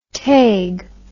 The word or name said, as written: taig